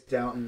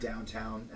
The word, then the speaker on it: Downton: By the way, it's Downton, not Downtown Abbey. Like, it's Downtown, but so it's Downton Abbey. I would pronounce it Downton Abbey.